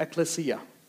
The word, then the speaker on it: ecclesia: The church, in the New Testament, the word that we translate as church is actually this word ecclesia or ecclesia. Depends on who you are. Ecclesia is the pronunciation I like.